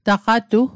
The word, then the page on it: taqatu: Mi'gmaq